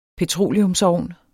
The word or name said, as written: petroleumsovn